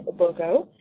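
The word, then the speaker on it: Ogbogoh: You can look for me under Facebook as well, under my full name, which is Lillian Obogo, and I'll spell that, which is LILLIANOGBOGOH.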